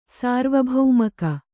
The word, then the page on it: ಸಾರ್ವಭೌಮಿಕೆ: sārvabhaumike